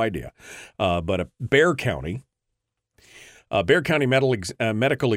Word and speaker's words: Bexar: It's spelled Bexar County, and I pronounced it Bexar County. But I was corrected in the chat room that it's Bear.